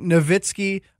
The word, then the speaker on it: Nowitzki: I would say that because it seems like people have a tough time pronouncing, I guess, German last names like Nowitzki, Wagner. They want to use that W sound, but, you know, who am I to tell them what to do?